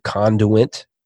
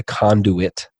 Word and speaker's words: conduit: And we believe that the local church is the conduit. How do you say that? The conduit. – Conduit. Yes, you got it second time. – I think I added an N in there somewhere. – Yep.